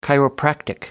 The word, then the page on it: chiropractic: British English